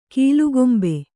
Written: ಕೀಲ್ಕಂಬ

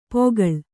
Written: ಪೋಕಳ

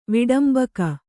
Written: ವಿಡಂಬಕ

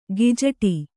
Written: ಗಿಜಟಿ

